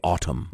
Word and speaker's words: autumn: I say autumn. – Wow. Fancy. – I would like to say autumn. I'd like to pronounce the N, because that's how much I love the autumn.